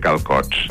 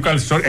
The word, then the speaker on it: calçot: Lo único que no entiendo mucho es lo de comer calcots. – Pero qué calcots. Es calçot. Es que no entiendes. Una cosa que tenéis también maca es la se trancada y no la pronuncia. Diu calcots.